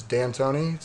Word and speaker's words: Dantoni: It can be pronounced both ways. To make it real simple, it's Dantoni. So just like take the apostrophe out because it gets really convoluted at that point.